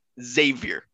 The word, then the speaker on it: Xavier: And he's like, it's pronounced – Xavier, like xylophone.